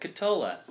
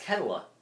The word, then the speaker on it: Ketola: That's not even how you pronounce my last name. – Cotola. – No, it's Ketola. – What? – Yeah. – No. – Yeah. – I've been saying it wrong for years. – So have I. – But I think it's Cotola. It's however you say your last name. – No, it's actually Ketola. That's Finnish. They do soft teas in Finland.